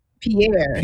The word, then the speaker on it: Pierre: So first is the heartbreaking death of Perry Damas Bell. Does it sound like I'm pronouncing that correctly? – Pierre. – Pierre Bell. You say he Haitian, that's Pierre for sure.